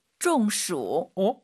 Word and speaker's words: 中暑: 네, 그래서 더위 먹다를 중국어로 中暑. – 가운데 중자가 근데 일성이잖아요. 원래 중국어 할때 中国, 중간, 中间. 근데 이거는 왜 사성이죠? – 这是多音字.